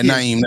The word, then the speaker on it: Naeem: Wait, how did y'all feel about Naeem? Am I saying his name right? – Yeah, Naeem, that's right.